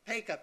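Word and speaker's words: Paca: Peka. – Peka. – P like Pete, Peter, A like Apple, C like Charlie, A like Apple.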